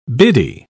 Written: biddy

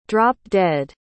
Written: drop-dead

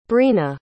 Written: breena

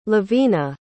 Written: levina

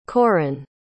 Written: corran

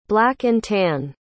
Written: black-and-tan

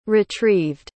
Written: retrieved

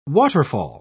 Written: waterfall

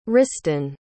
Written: riston

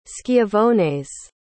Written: schiavones

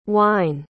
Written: wyne

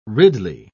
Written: ridley

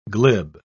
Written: glib

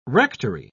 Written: rectory

